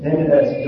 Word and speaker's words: Ms: And the Mrs. Dog is Miss Dog, M.S. Dog. Have you seen this in the Western countries? Instead of Mrs. and Miss, they put M.S. Dog. How is that pronounced? Have any idea? Ms. Ms. It means they don't want to keep any distinction between married and unmarried.